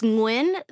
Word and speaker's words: Nguyen: It's not right, though. – It's Nguyen. The thing Nguyen. – Is, Nguyen. Like, there's a G stuck in the back of your throat that English speakers can't pronounce.